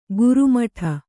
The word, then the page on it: ಗುರುಮಠ: Kannada